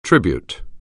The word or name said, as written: tribute